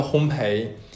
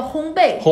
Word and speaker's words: 烘焙: 这样，嗯、呃，第一期的话，上上半期的话是跟大家讲一讲呃所谓所谓的烘焙。到底是怎么回事儿？ – 发音都错，叫烘焙。